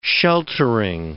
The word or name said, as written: sheltering